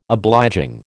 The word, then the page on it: obliging: American English